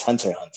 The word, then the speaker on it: Hunter: You don't pronounce that X, dude. So it's Hunter.